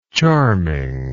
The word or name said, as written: charmingness